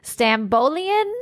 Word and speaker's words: Stambolian: And it was the famous off-the-shoulder figure-hugging black little number by Christina Stambolian.